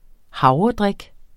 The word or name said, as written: havredrik